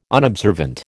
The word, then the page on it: unobservant: American English